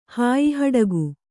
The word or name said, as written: ಹಾಯಿಹಡಗು